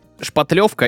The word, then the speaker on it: шпатлевка: Шпатлевка или шпаклевка, как правильно? – На самом деле и так произносится, обычно от слова шпатель, шпатлевка, но я также встречал шпаклевка, это просто вот прям используется везде.